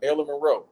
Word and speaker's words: Marilyn Monroe: Because I remember when the hologram came out, they tried to announce a movie, and they was going to have Marilyn Monroe.